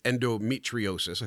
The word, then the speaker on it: endometriosis: So you you have spoken about your life with endometriosis. I think I said it right yes, that time. Endometriosis, yes.